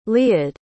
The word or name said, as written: leod